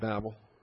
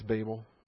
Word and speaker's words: Babel: Is that the Texas pronunciation? On the West Coast, we called it Babel. Okay, it's Babel.